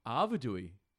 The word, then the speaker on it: Arvedui: Is that how you pronounce it? – Arvedui. – Yeah, no, there's no N. – There's definitely no N.